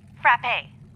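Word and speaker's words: frappé: Cubed ice or frappe? – I don't know what frappe is. – It's pronounced frappe.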